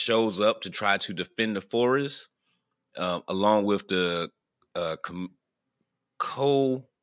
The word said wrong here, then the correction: Komori: clan. Is that how you pronounce that? – Komori. – Okay. The Komori clan to try to stop.